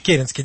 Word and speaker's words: Керенский: Дело в том, что эта фамилия искусственная. Возникла она от города Керенск на речке Керенке. И была она наименованием священника Керенского из города Керенск. Поэтому ударение от речки Керенки. А потом этот город переименовали в Воткинск, и, значит, это имя было стерто с карты России. Поэтому это именно Керенский. Так вот, оказалось, что очень легко бросать камнями в него, потому что он подставлялся всю жизнь сам.